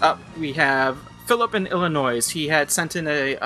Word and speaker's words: Illinois: Yeah, I think he said that the S is silent in his email. – Illinois, yes.